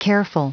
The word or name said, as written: careful